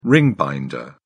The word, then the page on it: ring binder: British English